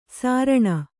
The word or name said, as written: ಸಾರಣ